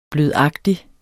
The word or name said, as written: blødagtig